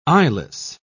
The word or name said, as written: eyeless